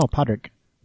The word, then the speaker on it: Padraig: No, Padraig. – So. – That's how you say it in Irish. Padraig.